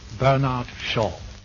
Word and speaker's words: Bernard Shaw: Bernard Shaw. – Именно так произносится эта древняя ирландская фамилия.